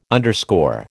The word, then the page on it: underscore: American English